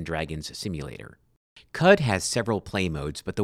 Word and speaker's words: Qud: Caves of Cud, that's spelled Q-U-D, and it is pronounced like the cow's chew toy,